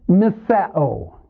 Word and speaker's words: Miseo: Miseo. The English equivalent would be M-I-S-E long O.